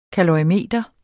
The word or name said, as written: kalorimeter